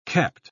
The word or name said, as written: kept